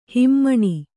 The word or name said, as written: ಹಿಮಾನಿ